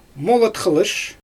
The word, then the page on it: molat xylysh: Xyzyl